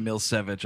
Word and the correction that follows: Milicevic: Here we have the how to pronounce it on IMDb. – Here we go. – Milicevic. Ivana Milicevic is this woman.